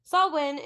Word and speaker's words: Samhain: Samhain is how you pronounce it.